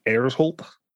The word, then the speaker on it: Hersholt: It's uh, the actually what's different is the way his last name is pronounced because it's Danish. It's actually like Erzholt.